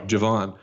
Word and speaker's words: Jevon: The addition of Jaren and Jevon. Is that how you pronounce it? – Javon.